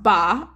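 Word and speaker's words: ba (八): Eight is pronounced ba in Mandarin Chinese, which sounds similar to fa, which means to make a fortune.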